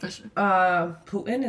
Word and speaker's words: Putin: But like, Putin, is that how you say his name, from Russia? – Yeah.